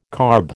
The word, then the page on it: carb: American English